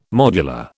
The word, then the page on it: modular: British English